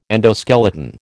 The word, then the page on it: endoskeleton: American English